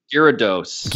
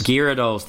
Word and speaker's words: Gyarados: At 5, and I don't know if I'm saying this right, Gyarados or Gyarados. – Oh, the big dragon. Yeah. The big blue dragon. Gyarados. – Gyarados.